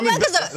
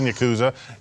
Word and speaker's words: Yakuza: Like, I've always called it Yakuza. But they were pronouncing it like Yakuza.